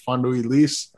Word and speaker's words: Fando y Lis: It's called Fando y Lis. Fando E-Y, and then Lis is L-I-S.